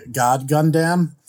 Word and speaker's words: God Gundam: First of all, you mispronounced it. It's actually God Gundam.